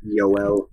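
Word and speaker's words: Joel: Kevi Ranta. Yoel. – Yoel. – Yeah, Yoel. – I'm glad he doesn't pronounce his name like mine. I'm glad he doesn't pronounce it like mine. – Yeah, it's Yoel Kevi Ranta.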